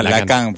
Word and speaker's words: Lacan: lo dije bien, Lacan. – no, ah, Lacan. porque acá hay un profe francés que me reta.